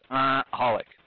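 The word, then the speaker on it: xxxHOLiC: Well, when I see that XXX Holic, I pronounce it as Holic.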